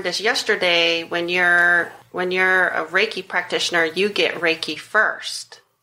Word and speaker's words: Reiki: Reiki, because I've said it several different ways.